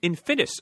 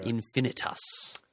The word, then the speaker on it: Infinitus: Also, he wanted to mention that he is on Justice League United now, along with Jeff Lemire at the moment, on the Infinitus Saga. – Infinitus. – Thank you, I'm so sorry.